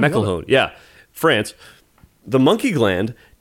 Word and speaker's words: McElhone: McElhone? How do we pronounce that? From Harry's New York Bar in Paris. – I think you nailed it.